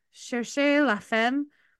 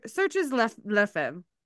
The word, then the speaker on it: chercher la femme: It's chercher la femme. But if you were just to read it phonetically, like in English, it's searches la femme.